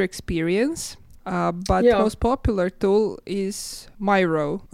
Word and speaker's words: Miro: And then they were like, yeah, like the artist, Miro. I think they said that, so let's stick to Miro.